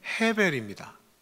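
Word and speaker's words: 헤벨입니다 (הֶבֶל): 헛되다라고 하는 히브리어 단어의 발음은 헤벨입니다. 그런데 이 히브리어의 에 발음은요 조금 약하게 발음될 때는 변형이 됩니다. 에로 발음이 나지 않고요. 그래서 짧은 아로 발음이 되거나 아니면 우리말이나 영어로는 표기하기 어려운 발음 어떻게 표현해야 될지 모르겠는데 굳이 표현하자면 우리말에 으하고 어하고 사이 정도에 있는 으 정도 되는 어떤 그런 약한 발음으로 이렇게 변형이 됩니다.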